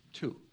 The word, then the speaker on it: two: Two. I think the word is two, isn't it? It's not do. It's two. – Two. Yeah. T-W-O. – Yeah. Two different W is silent.